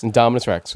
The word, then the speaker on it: Indominus Rex: Indominus Rex, yeah.